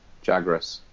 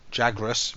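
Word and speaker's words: Jagras: So if you go out and hunt like a great jagger, you might have to correct my pronunciation on this one. – All right. Yeah. – Jagus, is it? – Jagrus. – Jagrus, right? You go and hunt one of them, and you chop it up, and that battle might take you 20 minutes.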